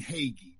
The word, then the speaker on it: Hagee: That man's name is John Hagee. That's how you pronounce his last name.